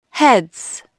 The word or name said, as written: heads